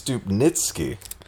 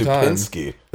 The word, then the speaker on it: Stupnitsky: Oh, it's Stupnitsky. – Yeah, I think we've been pronouncing it wrong every Stupinski.